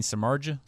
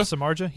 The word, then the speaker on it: Samardzija: I never say his name right. Sal Darza, the pitcher? – You mean Samardzija? – Samardzija? – Yeah, Samardzija. He was a former tight end for Notre Dame.